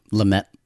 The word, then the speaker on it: Lumet: Lumet.